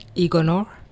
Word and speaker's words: Igonor: Igonor. – Igonor. Oh, that's not bad. Mm-hmm. It's just like it's spelled.